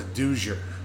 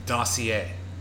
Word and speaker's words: dossier: And like, one of the one that sticks out the most is, because I always pronounced it wrong. I thought it was a Dozier. – Dozier? – Yes. Or a Dozier. But you know what it was? – No. – Dossier. D-O-S-S-I-E-R.